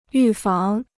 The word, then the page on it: 预防: yù fáng